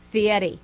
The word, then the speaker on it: Fieri: No. Say it right. Fieri.